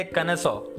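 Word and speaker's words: connoisseur: इसका प्रोनाउंसिएशन और इसके स्पेलिंग में बहुत ज़्यादा अंतर है प्रोनाउंसिएशन इसका है कनसो कनसो एंड स्पेलिंग अगर देखें तो इसका स्पेलिंग है सी O double N O I S S E U R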